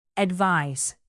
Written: advise

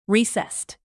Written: recessed

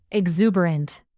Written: exuberant

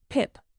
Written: pip

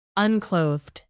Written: unclothed

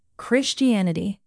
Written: christianity

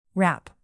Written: wrap

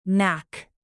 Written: knack

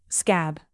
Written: scab

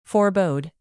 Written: forebode

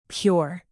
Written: pure

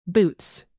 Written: boots

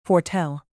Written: foretell

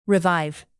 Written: revive

